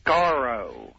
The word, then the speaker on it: Skaro: Skaro.